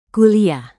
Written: kuliah

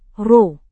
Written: roh

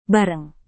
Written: bareng

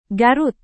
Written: garut

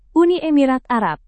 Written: uni emirat arab

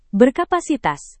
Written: berkapasitas

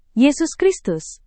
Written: yesus kristus

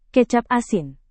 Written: kecap asin

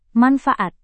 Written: manfaat

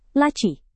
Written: laci

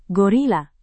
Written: gorila

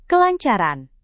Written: kelancaran

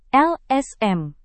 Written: lsm

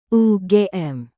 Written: ugm

